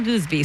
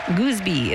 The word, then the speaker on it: Goosby: Goosby is spelled G-O-O-S-B-Y, by the way. And to make the pronunciation clear, his Instagram handle is It's Goose.